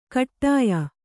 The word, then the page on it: ಕೂಟಾಯಿ: kuṭāyi